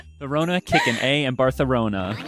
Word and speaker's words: Barcelona: Well, she's correcting me. She knew we'd read it wrong. Pronounced Barcelona, we have now learned. – Oh, Barcelona.